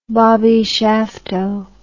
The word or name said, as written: Bobby Shafto